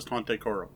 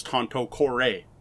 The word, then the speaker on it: Tanto Cuore: Um, to be honest, when I first learned the game, I thought it was Tante Quoro. That's how I pronounced it. That's whenever I was talking to anyone about the game. Not that it came up all the time. And it wasn't until Japanime Games sent me a review copy that I actually looked it up and found out it was Tanto Cuore all right, close enough.